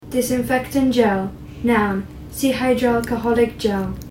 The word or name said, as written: disinfectant gel